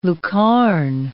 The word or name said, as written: lucarne